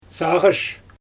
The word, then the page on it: saaGysh: Xyzyl